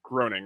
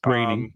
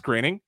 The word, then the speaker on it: Groening: Moving on to more nerddom in a way, Disenchantment, which was Matt Groening, right? Yeah, Matt Groening's Groening, but yes. – Oh, is it pronounced Groening? – Yes. Uh-huh.